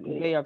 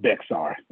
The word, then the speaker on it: Bexar: Bayard County. Is that how you said it? – Bexar.